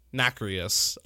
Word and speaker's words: nacreous: Nacreous.